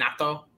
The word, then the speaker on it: Naito: That's how you say his name, Naito.